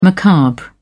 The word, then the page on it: macabre: British English